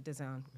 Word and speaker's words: DAZN: But that's how you pronounce it, DAZN.